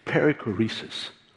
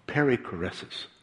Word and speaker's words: perichoresis: Did you know, there's a very big word, and I had to check the pronunciation. It's perichoresis, or perichoresis.